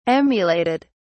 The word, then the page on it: emulated: British English